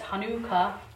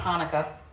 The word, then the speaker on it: Chanukah: So we are going to talk about Hanukkah, and that is the correct pronunciation. It's Hanukkah, not Hanukkah. The correct spelling is actually C-H-A-N-U-K-A-H.